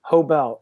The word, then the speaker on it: Hoebel: And it's just my name, Brett, B-R-E-T-T. Hobel, H-O-E-B-E-L. – Okay, I'm saying your name wrong. Hobel, I got it.